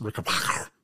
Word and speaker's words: Rickenbacker: It's pronounced Rickenbacker.